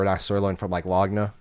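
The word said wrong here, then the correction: lagniappe: Lagniap. – Well, see, you say it one way. How do you say it? – Lagniap. – Lagniap. So whatever it's called. – It's French. The G would be silent.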